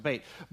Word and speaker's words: Bet: But it's a bet. Actually, it's pronounced bait.